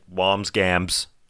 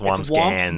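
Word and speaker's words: Wambsgans: Yeah, I said Wamsgams, and it's Wamsgans. – It's Burger. Oh, is it? Okay. – No, there's an N or an M. It's hard to say. It's just two syllables.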